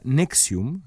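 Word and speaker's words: NXIVM: Ranieri eh, es el líder de una secta, eh, fundó una empresa llamada Nexium, así se pronuncia, aunque se escribe N-X-I-V chica -M que a su vez eh, incluía un programa de autoayuda llamado Executive Success Program o ESP, ESP por sus siglas en inglés.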